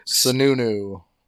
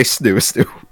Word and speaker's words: Sununu: Sununu. – Sununu. I was going to say Snoo-Snoo.